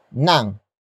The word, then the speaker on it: ng: The next word is nang, which is spelled as ng.